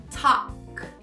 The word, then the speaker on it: talk: Talk. Você viu que eu vou do A direto pro K.